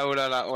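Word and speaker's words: Santaolalla: Petite mention spéciale quand même pour euh, l'OST, qui est fait, attention, par Gustavo Santaolala ou Santagnola, je sais pas du tout comment ça se prononce. – Santa Santaolala, ouais.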